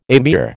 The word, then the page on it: amir: American English